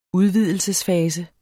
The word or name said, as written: udvidelsesfase